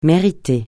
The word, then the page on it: mériter: francais